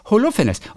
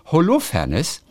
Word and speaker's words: Holofernes: und du hast immer gesagt Holofernes und ich habe die ganze Zeit ist gedacht, falsch.